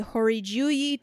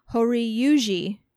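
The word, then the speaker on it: Hōryū-ji: Also, the reptilian statue of the Horiyuji Temple. Or wait, hold on. I said that wrong. Horiyuji Temple. – Horiyuji.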